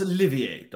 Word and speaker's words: Olivier: So, Laurence Olivier, darling.